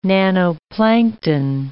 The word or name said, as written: nannoplankton